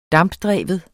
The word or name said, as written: dampdreven